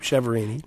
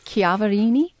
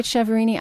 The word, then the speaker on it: Chiaverini: C-H-I-A-V-E-R-I-N-I. – That's correct. – And I am pronouncing it correctly, I hope, Chiaverini. – That is not the Italian pronunciation. The Italian pronunciation is Chiaverini. – Oh. – But my in-laws from Pittsburgh area pronounce it Chiaverini, and it was their name many, many years before it was mine. So if they want to say it Chiaverini, I am going with Chiaverini.